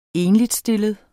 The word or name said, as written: ENLIGSTILLET